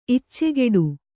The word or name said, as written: ಇಚ್ಚೆಗೆಡು